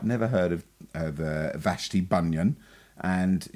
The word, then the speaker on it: Vashti Bunyan: Vashti Bunyan.